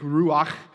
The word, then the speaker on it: ruach: Greek, ruach. You need to kind of be sick and phlegmy to pronounce that well.